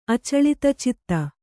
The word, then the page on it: ಅಚಲಿತಚಿತ್ತ: kannada